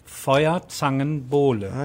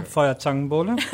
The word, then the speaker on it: Feuerzangenbowle: Feuerzangenbowle. – Okay, say that five times fast. – Yeah, Feuerzangenbowle.